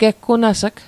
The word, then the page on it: geggunas'g: Mi'gmaq